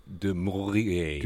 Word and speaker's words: du Maurier: So we have to talk about Daphne du Maurier. – Du Maurier, as she liked to pronounce it.